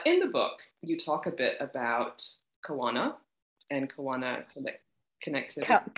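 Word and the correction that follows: Kaona: You're doing really well, though, pronouncing every vowel. So that's, yeah, it's just the, like, pushing them together a little more.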